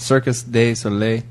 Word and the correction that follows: Cirque du Soleil: Cirque du Soleil. – Cirque du Soleil. Oh, I love that.